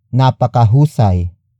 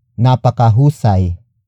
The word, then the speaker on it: napakahusay: Napakahusay umarte ni Nora. Napakahusay umarte ni Nora.